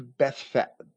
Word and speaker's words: Bethphage: and were come to Bethphage, which is probably the most accurate pronunciation in English that is loyal to the Greek. The G is always hard. It's never soft.